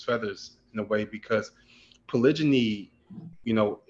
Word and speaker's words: polygyny: So in reference to polygyny, did I say it correctly? – Correct.